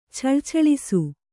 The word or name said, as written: ಛಳಛಳಿಸು